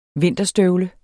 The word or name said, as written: vinterstøvle